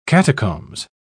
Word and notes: catacombs: British English

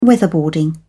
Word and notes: weatherboarding: British English

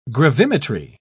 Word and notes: gravimetry: British English

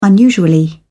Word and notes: unusually: British English

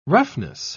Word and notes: roughness: British English

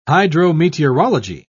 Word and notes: hydrometeorology: British English

